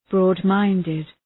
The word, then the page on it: broad-minded: ‘brɔ:d,maındıd